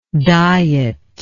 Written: dieter

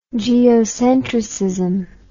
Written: geocentricism

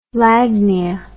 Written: lagnia

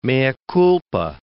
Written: mea culpa

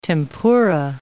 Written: tempura

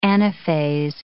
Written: anaphase

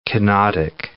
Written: kenotic

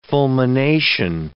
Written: fulmination

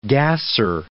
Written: gasser

